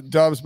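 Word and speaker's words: Doubs: Romeo, is it Dubs? – Dubs. Yes.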